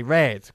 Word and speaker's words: read: They read. Como el color. Pronunciado igual.